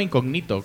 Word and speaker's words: Incógnito: De hecho, se llama Incógnito, con acento en la I.